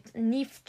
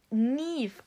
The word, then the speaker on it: Niamh: Ey, die haben sich so weggeschmissen, weil ich wusste halt vorher, dass die Nief zu dem Namen sagen, aber ich habe den halt noch nie so ausgeschrieben ja. gesehen. – Und Nief, also du sprichst es Nief aus und wird N-I-A-M-H geschrieben.